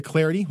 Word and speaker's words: Clardy: Or on Twitter at Troy Clardy, last name is spelled C-L-A-R-D-Y, at Troy Clardy or hashtag TreeCast.